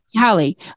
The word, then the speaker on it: Hawley: Hawley.